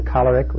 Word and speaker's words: choleric: And so we have the choleric, which is the right way to pronounce it. It comes from the thing where we get cholera from.